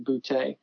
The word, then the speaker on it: Boutte: Um, one of the coolest was, um, Steve Boutte, uh, who's not on Instagram, but he builds, I believe he pronounces his last name Boutte. It's, um, B O U T T E.